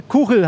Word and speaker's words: Kuchel: Ja, sag mal, die Kuchel, habe ich es jetzt richtig aus, fast richtig ausgesprochen, lieber Timo? – Jetzt hast du es.